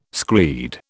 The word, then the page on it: screed: British English